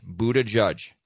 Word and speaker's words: Buttigieg: His name is spelled B-U-T-T-I-G-I-E-G, And it's pronounced Buddha, like Buddha, and then Judge, Buddha Judge.